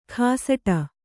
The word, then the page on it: ಖಾಸಟ: khāsaṭa